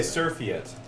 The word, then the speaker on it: surfeit: It's called a surfeit. – A surfeit? – Surfeit. – Yes. – A surfeit of skunks. – The E is not pronounced.